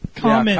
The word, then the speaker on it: Kamen: And actually, hold on, since I did spell it wrong, we are saying it incorrectly. It's... – Kamen? – Kamen. – Yeah, Kamen. – Kamen Rider.